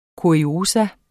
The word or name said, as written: KURIOSA